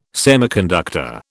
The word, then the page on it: semiconductor: British English